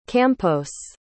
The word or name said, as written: campos